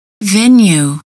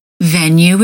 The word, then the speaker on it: venue: Venue. Venue is also pronounced with a long U.